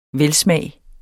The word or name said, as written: velsmag